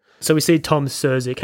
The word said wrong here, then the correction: Skerritt: Tom Skerritt.